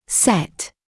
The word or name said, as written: set